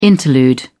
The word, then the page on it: interlude: British English